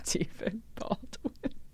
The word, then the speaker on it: Stephen Baldwin: So now that we've confirmed that his name is pronounced Stephen Baldwin, now we can move on.